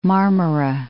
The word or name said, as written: Marmara, Sea of